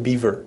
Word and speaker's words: Bieber: Somebody was mispronouncing it. I was like, man, we're really out of touch. Justin Bieber. I was like, yeah, Bieber. It's pretty good.